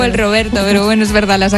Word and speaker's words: Roberto: Mira, en primero de italiano nos enseñaron que la R, cuando era, venía sola, dilo. Era un Roberto. – Roberto. – O sea, se pronuncia como si fuera nuestra R suave.